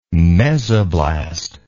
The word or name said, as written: mesoblast